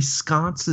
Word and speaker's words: Sconce: And so when Adam and Jeffrey Sconce, is that how you say his name? – Yeah.